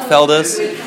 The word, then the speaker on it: Feldes: Mark Feldes. – How do you spell that surname? So Mark, it's M-A-R-C. – So M-A-R-C and F-E-L-D-E-S.